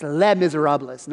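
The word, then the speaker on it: Les Misérables: Hey, listen, if you want to be cultured, it's the S is silent. It's Les Miserables, not Les Miserables.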